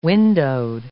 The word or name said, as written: windowed